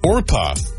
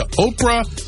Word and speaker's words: Orpah: It was Orpah. O-R-P-A-H. It's the Bible story of Ruth. That is a name in that story, but relatives kept mispronouncing it to Oprah, and apparently that's what she went with.